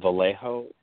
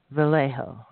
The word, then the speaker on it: Vallejo: It's pronounced Vallejo. The J is silent. – Vallejo.